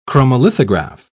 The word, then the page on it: chromolithograph: British English